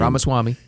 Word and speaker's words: Ramaswamy: I can never say his name. – Ramaswamy.